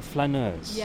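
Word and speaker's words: flâneuse: Now the word, am I getting this right? Flaneuse. – Yes, that's right.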